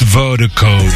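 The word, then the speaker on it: Virdiko: That's V-I-R-D-I-K-O, pronounced Vertico.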